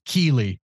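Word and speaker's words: Kíli: And very, sure, very soft 16, but still made my list, is Keeley from The Hobbit. – That's not a bad book, pick at all. – Book Keeley, yeah, totally unmemorable, other than the fact that it rhymes with Feely.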